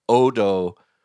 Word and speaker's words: Odo: Sushi Note is one in Sherman Oaks, and the other is Odo, which I think is actually pronounced O-D-O, in Chelsea, Flatiron area in New York.